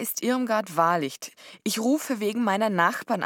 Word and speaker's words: Wahlicht: Der Nachname ist Wahrlicht. Das schreibt man W-A-H-L-I-C-H-T.